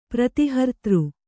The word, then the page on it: ಪ್ರತಿಹರ್ತೃ: prati hartř; Kannada